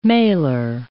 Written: mailer

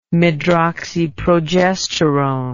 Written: medroxyprogesterone

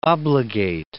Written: obligate

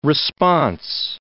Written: response

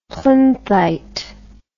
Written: plinthite

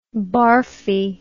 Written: barfy

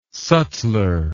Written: sutler

